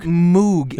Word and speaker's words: Moog: Not Moog. – It's spelled Moog. M-O-O-G. – I'm with you. I have been calling it a Moog all of my life, but it's pronounced Moog.